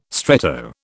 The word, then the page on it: stretto: British English